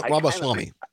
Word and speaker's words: Ramaswamy: Viva Ramaswamy.